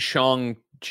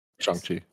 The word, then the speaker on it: Shang-Chi: Maybe we'll see him again in Shang-Chi. Did I say it right? – Yeah. Mm-hmm. Shang-Chi.